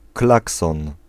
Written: horn

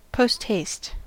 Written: post-haste

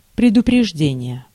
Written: notice

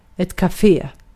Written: kahve

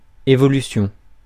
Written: évolution

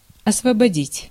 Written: exempt

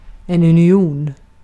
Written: birlik

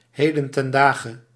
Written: nowadays

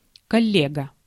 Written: fellow

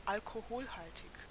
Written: alcoholic

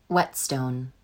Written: whetstone

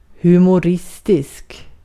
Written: humoristisk

